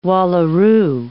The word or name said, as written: wallaroo